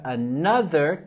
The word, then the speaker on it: another: Con la palabra another, queremos subir la voz, entonación en la segunda sílaba. Another. Tenemos los sonidos vocálicos en la palabra another, también con el sonido TH sonoro.